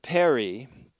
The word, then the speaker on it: peri: Next is the genitive preposition peri, or peri, P-E-R-I, peri, which means concerning or about.